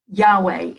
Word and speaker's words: Yahweh: Yahweh is how we typically will pronounce it.